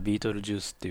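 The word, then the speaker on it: Betelgeuse: ベテルギウスはね、英語ではビートルジュースっていうふうに発音しますけれども、日本語ではラテン語由来のベテルギウスという呼び方をします。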